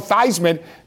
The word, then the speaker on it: Theismann: You guys know him as Joe Theismann. Joe Theismann changed his name to rhyme with Heisman.